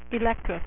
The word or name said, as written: Electro